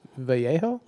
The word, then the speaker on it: Vallejo: Which I feel like should be pronounced Vallejo? – Mm-hmm. Yeah, it's weird that they don't pronounce it with the Spanish. – Californians. – Spanish word, but not pronounce with the Spanish-like inflection.